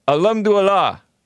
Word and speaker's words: Alhamdulillah: Alhamdulillah.